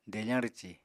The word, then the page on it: defañ rwci: apatani